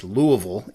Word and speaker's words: Louisville: Um, I live in Louisville, Kentucky. Uh, which I did not pronounce correctly. Everybody in this area is probably mad at me right now. It's it's pronounced Louisville if you live in this area.